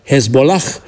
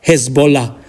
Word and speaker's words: Hezbollah: Dar mult mai aproape de noi să amintim acel H, H final, pur grafic, care nu se aude, din Hezbollah, partidul șiit fundamentalist libanez. Foarte mulți pronunță acolo H-ul final, Hezbollah, când, de fapt, sonor e doar Hezbollah.